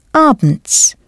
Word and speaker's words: abends: In the evening, or evenings, is abends. Do pronounce the S clearly.